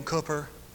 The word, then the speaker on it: Cowper: William Cooper, spelled C-O-W-P-E-R, but it's pronounced Cooper. English spelling of Cooper.